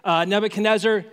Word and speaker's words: Nebuchadnezzar: And they went there not on vacation, but because someone, I know this is hard to pronounce, Nebuchadnezzar is a fine way to pronounce it. Nebuchadnezzar, like, forced them out.